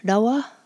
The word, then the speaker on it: nawa: But the English-speaking settlers couldn't pronounce that, so they transliterated it as nawa, and that's how we pronounce it today. Languages evolve, and Pawnee is no different. It's actually easier for me to get the sound if I pronounce it with the R, the rawa, rawa. With the N, it has a tendency to come out more nawa, nawa.